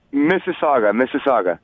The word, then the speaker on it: Mississauga: How do you say the city? Mississauga. – Mississauga. – Mississauga. Okay. Well, yeah. M-I-S-S. Yeah, it's like our M-I-S-S-I-S-S-I-P-P-I, the American version.